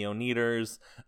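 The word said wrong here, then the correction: Oneders: Hey, by the way, that's the Oneters. – Ah, my mistake. I always pronounce that wrong.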